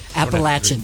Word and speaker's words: Appalachian: And I was like, did I say that right? – Uh-huh. – You made me sweat. You said it right. – Appalachian.